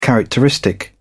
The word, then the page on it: characteristic: British English